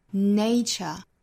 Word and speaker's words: nature: 我们知道由它而来的一个形容词的发音呢是 natural，那里面的元音是 a，但是这个词名词形式第一个元音是 a，双元音 nature，所以不要混淆这两个词的发音了。